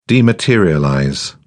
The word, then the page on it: dematerialize: British English